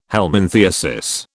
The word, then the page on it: helminthiasis: British English